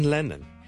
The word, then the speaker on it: Lennon: 第一个字咧就系、是、人名嚟嘅，John Lennon，John Lennon，John Lennon，一个英国嘅好出名嘅歌手作曲家啦，Lennon，L-E-N-N-O-N。第一个系 L 音，所以唔好将佢读成 N 音，唔系 Lennon，系 Lennon，Len，Len，Len，Len，Len，Len，Len，Len，Len，后面嗰个先系 N 音，所以。 Lennon 仲要两个 N 音添，Lennon 第一个 L，咧咧咧咧咧，Lennon Lennon Lennon Lennon Lennon Lennon，